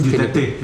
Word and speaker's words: Duterte: Duterte, yeah.